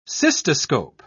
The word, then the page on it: cystoscope: English; British English